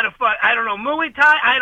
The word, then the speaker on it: Muay Thai: How to pronounce Muay Thai. – Yes. Muay Thai. No, right. It's pronounced Muay Thai. – He said Muay Thai. – That's how it's pronounced.